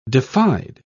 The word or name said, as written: defied